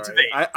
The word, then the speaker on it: Tveit: Also Aaron Tveit. – Yeah. – Tveit. It's definitely Tveit. – As I was saying it, I realized I was saying it wrong, so don't worry.